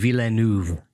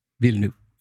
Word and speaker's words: Villeneuve: And I had always thought of it as Denis Villeneuve or something like that. And as most people actually pronounce it with this sort of three-syllable last name. The way he said it, unless he was just tired or what, he doesn't care. He just said Denis Villeneuve. Villeneuve. So it sort of drops the middle.